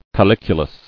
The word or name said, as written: Calyculated